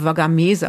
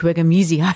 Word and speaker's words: Wagamese: Der heißt Richard Wagamese und ich habe es immer indianisch ausgesprochen, Wagamese, aber das stimmt nicht, Wagamese heißt, der wird ja schon auch englisch ausgesprochen. Der gefrorene Himmel,